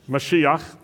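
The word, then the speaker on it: Mashiach (מָשִׁיחַ): How do you say Messiah in Hebrew? Mashiach, that's right.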